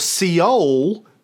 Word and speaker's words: Seoul: a group of Korean activists in Seoul, or Seoul, as it's pronounced in a lot of old American newsreels from the time, Seoul, protested publicly about the Japanese occupation.